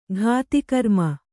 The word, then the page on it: ಘಾತಿಕರ್ಮ: kannada